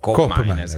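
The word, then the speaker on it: Koopmeiners: Qui parliamo fiammingo, che è l'olandese, per la cronaca colgo l'occasione per dire che di Cop Miners, la doppia O di Cop Miners invece si legge U. – Oh. – No, no, O si legge O, invece O si legge U. Quindi Cop Miners è corretto chiamarlo Cop Miners.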